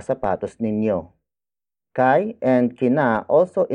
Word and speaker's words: sapatos: Mga is spelled as M-G-A. It is the plural marker for the next word, which is sapatos. Sapatos. Sapatos. Sapatos is spelled as S-A-P-A-T-O-S.